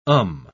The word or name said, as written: um